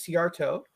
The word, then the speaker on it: Szijjarto: My surname is Ciarto.